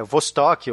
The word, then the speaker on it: Vostok: Vostok, o, o pronúncio russo é Vostok, que basicamente é, é, é uma nave também, uma lata de sardinha, só que é diferente da Mercury porque ela é redonda.